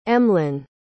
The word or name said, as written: emlyn